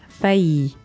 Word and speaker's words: failli: Failli.